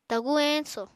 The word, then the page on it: tagur eñso: Apatani